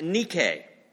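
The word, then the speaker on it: Nike (νίκη): The word victory in Greek, in the New Testament, Koine Greek, is pronounced Nike.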